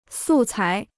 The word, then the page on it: 素材: sù cái